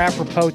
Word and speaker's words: apropos: All right, so another thing that's developing, and this is more apropos to the time on the calendar, McMahon, so you'll be happy about that. Trade deadline's coming up in 3 weeks. – Why don't you pronounce the S in apropos? – Pure Texan.